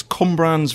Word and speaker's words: Cwmbran: It's Cwmbran's very own Jack Jones.